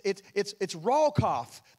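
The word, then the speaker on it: rawkoff (רחף): And it's rawkoff. That's the Hebrew pronunciation.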